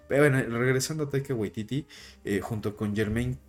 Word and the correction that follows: Jemaine: Clement, que es mejor con Jemaine Clement, no sé si lo estoy pronunciando bien, ambos son neozelandeses.